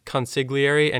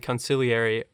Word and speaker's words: consigliere: And there's another pronunciation that I worried about that's consigliere and conciliary. They say both of those in the film as well.